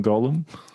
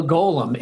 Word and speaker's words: Golem: So the Golem is a thing. And in Lord of the Rings, it's Gollum, spelled differently, pronounced differently.